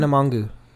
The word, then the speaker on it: Tana Mongeau: Tana Mongeau.